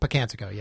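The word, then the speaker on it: Pocantico: Pocatino, is that what it's called? – Pocatico, yeah.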